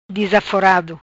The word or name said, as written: Dizaforado